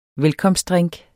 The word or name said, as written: velkomstdrink